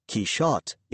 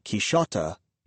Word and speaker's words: Quixote: Quixote, spelled Q-U-I-C-H-O-T-T-E and pronounced Quixote in French and Quixota in German, and C-H-I-S-C-I-O-T-T-E, pronounced Quixote in Italian, are alternative spellings slash pronunciations of the Spanish Q-U-I-X-O-T-E or Q-U-I-J-O-T-E, pronounced Quixote.